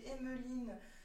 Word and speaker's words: Emeline: Donc j'ai une page Instagram aussi au nom d'Emeline Ailig, a e i g euh, Emeline, comme ça se prononce.